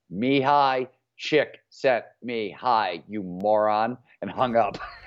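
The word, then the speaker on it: Csikszentmihalyi: Chick sent me hi. So it's Mihaly, Chick sent me hi with an E at the very end of it.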